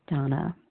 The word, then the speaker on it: Dana: This is Donna B., recovered compulsive overeater and bulimic, calling from Denver, Colorado. I do spell my name D-A-N-A. Although it's pronounced Donna.